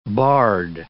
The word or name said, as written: barred